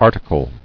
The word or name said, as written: Articled